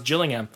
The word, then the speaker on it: Gillingham: You know, Drew was very, very sure that Gillingham was pronounced Gillingham when he was actually completely incorrect. It's pronounced Gillingham. – All right.